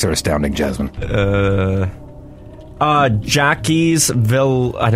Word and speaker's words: Jacques Villeneuve: J A C Q U E S. Jacques. Jacques. Jacques. Jacques. Notros. Jacques Villeneuve. Villeneuve. – It's pronounced the same way as Denis Villeneuve.